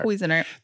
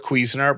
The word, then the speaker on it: Cuisinart: Is it Cuisinart or Cuisinart? – Cuisinart. – They're both Cuisinart brand, and they're my two favorite kitchen things next to my air fryer.